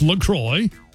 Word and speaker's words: LaCroix: Officially, it's pronounced LaCroix, so it rhymes with enjoy.